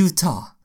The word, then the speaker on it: Utah: Ja, det är Utah.